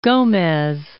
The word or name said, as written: Gomez